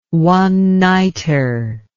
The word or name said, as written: one-nighter